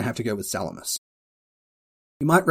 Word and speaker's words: Salamis: And it is pronounced Salamis, even though it looks like Salamis.